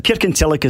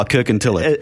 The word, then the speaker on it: Kirkintilloch: Oh, well, I'll try. Kirkintilloch. Is that, or Kirkintilloch? How would you say that? – Kirkintilloch is it. – Oh, Kirkintilloch.